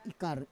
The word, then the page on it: ikar: Roon